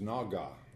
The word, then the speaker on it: Nogah: Now, that word for light is, or for brightness, is Nogah, N-O-G-A-H, and that's the way we would pronounce it.